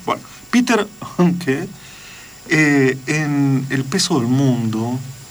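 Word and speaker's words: Handke: No es Peter Hanke. – ¿Cómo es? – Es Hanke.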